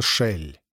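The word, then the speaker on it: Шелль: Его имя пишется Кель, но с типичным для него духом противоречия произносится Шель.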